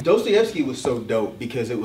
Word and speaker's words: Dostoevsky: Even though I love Black Thought, but he mispronounces his name. I thought it was funny. I think he called like Dostoevsky. It's Dostoevsky.